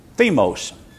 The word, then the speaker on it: thumos: And the thumos, the E in there is pronounced thymos.